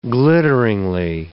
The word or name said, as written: glitteringly